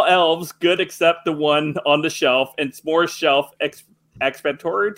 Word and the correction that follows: self-explanatory: Did I say that right? Probably not. – Self-explanatory.